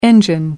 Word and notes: engine: American English

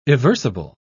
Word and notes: eversible: British English

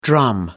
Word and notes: drum: American English

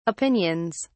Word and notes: opinions: British English

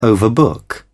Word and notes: overbook: British English

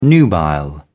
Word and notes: nubile: British English

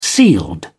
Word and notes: sealed: British English

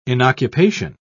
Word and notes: inoccupation: British English

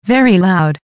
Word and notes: very loud: American English

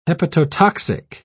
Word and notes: hepatotoxic: British English

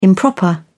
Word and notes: improper: British English

